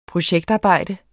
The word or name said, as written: projektarbejde